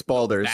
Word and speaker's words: Baldur's: It's Baldur's.